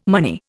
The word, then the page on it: money: Indian